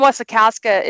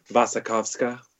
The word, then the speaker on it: Wasikowska: So you're mispronouncing her last name. – How do you say Wasikowska? – Wasikowska. – I've never heard it pronounced that way. You're the first person I've heard say it. – It's because she's Eastern European. I heard her say it once and it stuck with me.